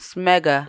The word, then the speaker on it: smegma: I couldn't. Sigma, I think, is the way you pronounce it. – Sigma. – It's S-M-E-G. – Smegma. – Smegma. Okay.